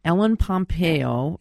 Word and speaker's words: Pompeo: Ellen Pompeo.